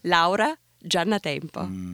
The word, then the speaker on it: Giannatempo: Can you just say your name one more time in Italian? – Yes. – Io? Laura Giannatempo.